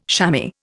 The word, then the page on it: shammy: Indian